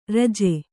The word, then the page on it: ಋಜು: řju